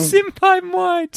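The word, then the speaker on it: symbiote: Symbioite? Am I saying it wrong? – Symbioite. – Symbioite? – Yeah, that's it.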